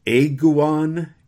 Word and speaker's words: Ayguan: First of all, he mentions an Italian exegete with whom I was not familiar, a fellow named Michael Aiguan. It's spelled A-Y-G-U-A-N.